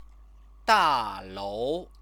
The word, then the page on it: dalou: chinese